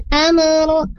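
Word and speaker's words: amru (الأمر): Nah, amro jangan dipentulkan huruf mimnya saat sukun. Tapi am Zalikal amru anna dabiru haula. Tadi yang Alin bacanya wa qadayna ilaihi zalikal amru seolah-olah ada pentulan sedikit, meskipun hanya sedikit, tapi itu tidak ada pentulan ya, karena di sini bukan huruf qalqalah huruf mim.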